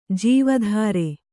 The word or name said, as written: ಜೀವತರು